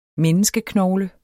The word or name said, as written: menneskeknogle